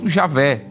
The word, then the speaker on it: Javé: Entretanto, aos poucos, esse nome vem sendo substituído pela forma Yahvé ou Javé, que é a pronúncia mais próxima dos originais, conforme alguns especialistas do hebraico bíblico.